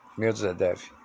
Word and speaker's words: Medvedev: Medvedev.